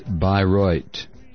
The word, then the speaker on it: Bayreuth: I was talking about the dog poo, the American flags and dog poo, and a town in Germany, Bayreuth. It's pronounced Bayreuth.